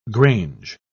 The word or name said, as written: grange